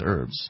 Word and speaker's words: herbs: Which we don't have even that with like herbs such as, or herbs as some would say, like basil and oregano. – I think they would be saying it incorrectly. It is herbs. – Oh, insists that it's herbs. It has an H.